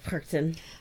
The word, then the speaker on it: Perchten: How would you pronounce that in German? – Where? – It looks like Perchten. – Yeah, like Perchten.